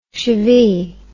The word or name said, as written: shivee